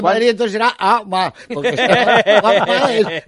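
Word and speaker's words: Juan Ma (皇马): Y a que no sabéis cómo se dice Real Madrid en chino, de verdad, no es un chiste. No es un chiste, porque además me dicen que han estado supervisándolo traductores chinos. – A ver. – Se dice Juan Ma.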